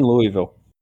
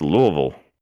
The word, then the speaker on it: Louisville: You mispronounce Louisville. – I mispronounce a lot of words. – Louisville. Okay, I'll stop that.